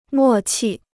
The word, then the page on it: 默契: mò qì